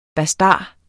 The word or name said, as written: BASTARDERNES